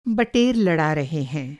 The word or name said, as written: बटेर